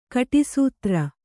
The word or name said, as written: ಕಟಿಸೂತ್ರ